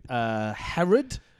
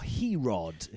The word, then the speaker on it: Herod: I have been listening to Herod. – Ooh. – Or Herod, if you pronounce it incorrectly.